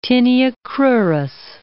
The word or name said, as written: tinea cruris